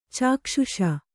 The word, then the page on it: ಚಕ್ಷ್ಷು: caksh'u